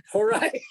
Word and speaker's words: Horai: How do you say it? Horai? – Horai.